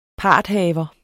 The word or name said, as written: parthaver